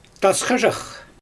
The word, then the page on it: tasxaʒax: Xyzyl